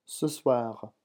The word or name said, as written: ce soir